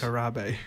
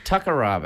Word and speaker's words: Mia Takarabe: How do we pronounce it, boys? – Takarabe. – Takarabe, there we go.